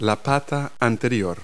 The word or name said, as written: foreleg